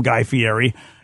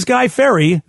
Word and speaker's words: Guy Fieri: I think when John Krasinski had him on his show, I think he called him Guy Fieri. And nobody knows how to say his name. But it really is Guy Ferry.